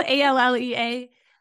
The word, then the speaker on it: Allea: So it's spelled Allea, but it's short for Allison Lee.